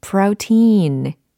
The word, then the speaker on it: protein: 자, 모음 부분은요. prow 이렇게 이중 모음화 시켜주시면 좋아요. prow 그다음 protein. 여긴 장음화 시켜주시면 좋아요. 그래서 protein, protein 이겁니다.